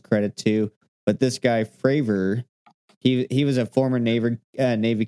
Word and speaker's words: Fravor: I was wondering if it was possibly spelled like Favre, like Brett Favre. – I keep saying it wrong. FRAVOR. – Oh, so it's like Fravor.